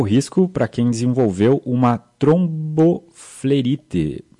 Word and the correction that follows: tromboflebite: trombobite, eu acho que é, né? Flebite, doutor. O senhor depois explica qual é que é a pronúncia certa na perna e qual também o tratamento para essa doença.